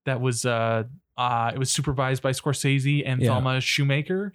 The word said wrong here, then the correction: Schoonmaker: Shoemaker, yeah. Could be Schumacher, but Schoonmaker. Thelma, sorry.